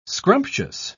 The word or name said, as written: scrumptious